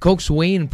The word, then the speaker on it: coxswain: So, did you know that Coxswain, pronounced as Coxswain, mm-hmm, mga kapwersa?